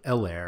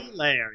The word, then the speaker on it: Helaire: Like, I get that the H is silent and I can maybe say it too much, but like, he was so emphatic about it. It was really strange. – Well, it's also like, just because the H is silent doesn't mean it's a hard E. – Right. – Like, most of us don't say Helaire. We'll just say like Helaire.